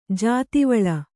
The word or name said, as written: ಜಾತಿವಳ